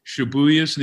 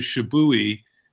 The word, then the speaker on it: Shibuya: There's a guy, Chris Tolis, who could not for the life of him pronounce Shibuya's name. He pronounced it as Shibui.